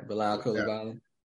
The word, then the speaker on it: Bilal Coulibaly: Um, uh, Victor Wembanyama's teammate, um, I can't pronounce his first name, but Kubulali. Yeah, Bilal Coulibaly.